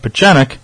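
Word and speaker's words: Pieczenik: State Department official Steve Pachenik, that's how you pronounce his name.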